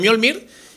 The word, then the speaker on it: Mjolnir: Estoy diciendo Mjolnir, intentando pronunciarlo bien. Yo he dicho Mjolnir de toda la vida, pero creo que como con la I está bien dicho.